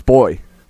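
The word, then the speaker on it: Boi: Is it Bua? – I think it's Boy. I think it's Big Boy. – Boy. – Yes, it is. Big Boy from Outkast.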